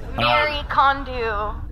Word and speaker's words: Marie Kondo: It's actually spelled Marie, pronounced Mary. – Okay. Okay. That's my fault. – Marie Kondo.